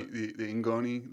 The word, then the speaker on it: ngoni: But there's the kora, and is it the ngoni? – Yep, the ngoni.